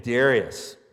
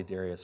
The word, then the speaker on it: Darius: There's a man named Darius, or Darius, depending on how you want to pronounce it. I'll say Darius for us.